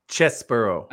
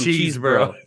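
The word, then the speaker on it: Chesebro: Chesbro. – I usually call him Cheesebro. No, I know it's not. Chesbro. I like to call him Cheesebro. – I do too. Chesbro.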